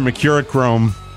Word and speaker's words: Mercurochrome: So get your Mercurochrome.